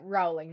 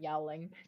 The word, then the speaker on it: Rowling: Yowling from, and it said Rowling, not Yowling.